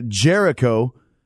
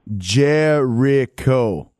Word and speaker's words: Jericho: Is this the real Chris Jeringo? – It's actually Jericho, but I know you're from Italy, and sometimes you have a problem pronouncing names, but Jericho. – Jeringo. – Jericho.